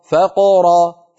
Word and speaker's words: فقر: الفقر، يعدكم الفقر، فقر، فقر، فنفتح الفم عند الفاء، نقلقل القاف من غير فتح الفم، ثم نفتح الفم مرة ثانية من أجل حرف الراء.